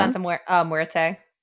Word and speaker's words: Santa Muerte: How do you say that? Do you know? – Santa Muerte. Death. – Muerte. Yeah. Yes.